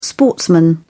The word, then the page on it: sportsman: British English